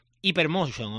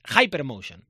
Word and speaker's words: Hypermotion: Esto hay que pronunciarlo bien, además, porque hemos hecho un curso, ¿eh?, para esto. No es Hypermotion o algo, no, es Hypermotion, la Liga Hypermotion.